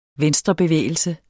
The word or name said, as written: VENSTREVENDT